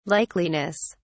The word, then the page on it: likeliness: British English